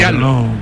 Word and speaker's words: giallo: Giallo.